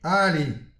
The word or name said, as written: ali